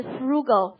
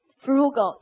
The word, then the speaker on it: frugal: 就是它，看它发音。是六级重点单词 frugal 腐乳就够了，对不对？是不是很节省呀？我每天早上我不买菜，我就买腐乳，对不对？买块豆腐乳，然后拿它拌点粥一喝，是不是？就像喂狗一样，好就吃了。 frugal 记住叫节俭的，看它发音 frugal，对不对？